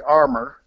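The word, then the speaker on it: Armour: We pronounce it a little cruder over here. We armor, not as you would pronounce it in Europe, obviously.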